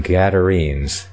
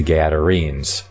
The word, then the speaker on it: Gadarenes: I kind of wonder about that name, Gadarenes. Gadarenos. That's the pronunciation I get from the Greek there.